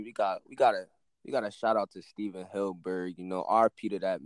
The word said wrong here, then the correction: Hillenburg: Yes, RP Steven Hillenburg, right? Hillenburg, yeah.